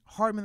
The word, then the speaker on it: Hardman: But the reason why I like Diggs, when I look at the AFC receivers that made the Pro Bowl, Hardman, that's how you say it, right?